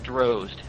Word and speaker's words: Drozd: It's pronounced Drozd, rhymes with nose.